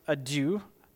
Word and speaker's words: idou: I believe it's pronounced adou, I-D-O-U.